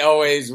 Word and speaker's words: Elwes: I pronounce it in the UK, Ulz, but it might not be. – Yeah, Kerry Elwes, we pronounce it here.